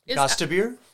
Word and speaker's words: Gostisbehere: Shane Gostisbehere? – Gostisbehere? – If you say so.